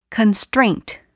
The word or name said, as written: constraint